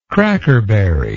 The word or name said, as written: crackerberry